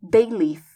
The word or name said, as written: bay leaf